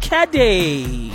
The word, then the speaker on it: Caddy: My name is C-A-D-D-Y, pronounced Kade.